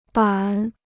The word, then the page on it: 板: bǎn